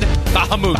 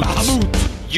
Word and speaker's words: Bahamut: So remember, they are pronounced Kujata, Kujata. and Bahamut. Bahamut.